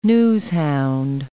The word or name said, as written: newshound